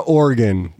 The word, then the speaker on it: Oregon: And Oregon's like, nobody cares. No one cares. – It's pronounced Oregon. So Oregon.